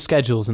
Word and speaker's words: schedule: It's all to do with the schedules and things, isn't it, Phil? I mean, schedule is correct. That's correct in the British pronunciation.